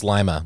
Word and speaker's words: Lima: It's pronounced Lima. Lima bean.